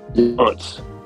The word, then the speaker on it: Younts: Younts.